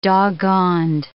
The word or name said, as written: doggoned